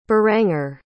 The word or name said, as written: beranger